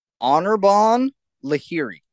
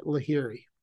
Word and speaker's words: Anirban Lahiri: Anirban Lahiri. Yes, that's it. Lahiri.